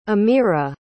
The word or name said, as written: amirah